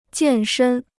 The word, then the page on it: 健身: jiàn shēn